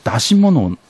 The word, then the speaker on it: 山車: で、まあ、これに対してその出汁というのはですね、まあ、祭りの際にそのですね、使われるその出し物のことを出しというふうに言ったんですね。で、山を車に乗せて引き回すというふうに書いて、これで山車と書いて出しというものですね。あるいはこれで山車と書いて単に山とも読ませておりますね。